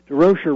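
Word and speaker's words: Durocher: And he says, no, but DeRocher rhymes with kosher.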